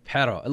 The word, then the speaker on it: Perreault: Nice pronunciation there. Jacob Perreault. It looks like you'd see for Gilbert Perreault.